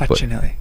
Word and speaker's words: Facinelli: So Al Roker tomorrow, Peter Facinelli. I might be mispronouncing that. – Facinelli. Don't say it like Longoria.